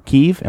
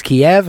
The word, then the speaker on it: Kyiv: Am I saying that right? In Ukraine? – You did. I was corrected harshly by the news junkie when I tried to tell him it's pronounced Kiev, and he said, Actually, that's wrong.